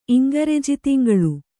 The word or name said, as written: ಇಂಗರೆಜಿತಿಂಗಳು